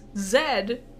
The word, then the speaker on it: Zed: Jam Jars does a rhyme, but he uses Zed and rhymes it with head.